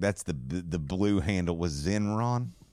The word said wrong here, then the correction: Zeron: Zeron. – There you go.